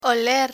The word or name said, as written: oler